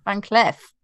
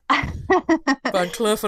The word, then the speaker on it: Van Cleef: They had to pull me aside and be like, right, okay, how are we saying that again? Like, trust me, Van Cleef. – All right, fine. Van Cleef & Arpels it is.